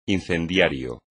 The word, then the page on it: incendiario: espagnol